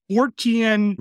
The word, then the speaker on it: Fortean: Fortean refers to Charles Fort.